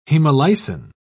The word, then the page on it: hemolysin: British English